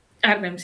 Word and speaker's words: Arnhem: 18 años, estudiando una ingeniería de sistemas en Arnhem. ¿Lo he pronunciado bien? Arnhem. – Arnhem, sí, ahora.